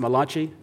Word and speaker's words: Malachi: You said it's pronounced Malachi, right? I thought it was Malachi, but, you know, he helped me out.